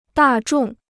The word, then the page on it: 大众: dà zhòng